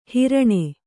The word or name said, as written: ಹಾರಣಿ